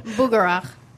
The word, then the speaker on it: Bugarach: Bugarach.